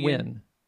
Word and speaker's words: Nguyen: Well, it's pronounced Nguyen. Wynn. – Oh. Really? – Yeah. Yeah. It's pronounced Wynn, but it's spelled Nguyen.